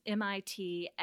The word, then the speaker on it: Mitsui: So it's Mitsui.